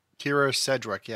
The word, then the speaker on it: Sedgwick: Kira Sedgwick, yeah.